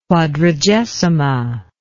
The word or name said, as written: quadragesima